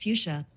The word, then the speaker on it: Fuchsia: how do you pronounce fuchsia? – That's pronounced fuchsia.